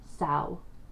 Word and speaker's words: Samhain: But yeah, so Samhain is actually pronounced Samhain. As a note, so that cool. You don't do what I did for a very long time and pronounce it completely wrong.